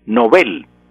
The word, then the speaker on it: Nobel: Todo el mundo dice el premio Nobel, Nobel, y Nobel es incorrecto en español. ¿Por qué? Porque en, al pasar un idioma o el nombre extranjero al español se pronuncia como se pronuncia en su idioma original. Entonces en sueco es Nobel, y así debe pasar al español, Nobel.